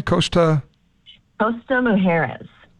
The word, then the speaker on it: Costa Mujeres: Costa Mujeres.